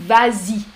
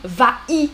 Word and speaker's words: vas-y: On dit vas-y c'est pour l'oreille. Hmm. Va-i, c'est difficile à prononcer. Va-i, alors on a rajouté le S pour la prononciation. Vas-y.